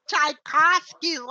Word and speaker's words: Czajkowskyj: Czajkowskyj, like the composer.